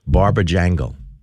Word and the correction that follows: Barabajagal: Barabba Jangle. Let me say it correctly. Barabba Jangle. B-A-R-A-B-A-J-A-G-A-L.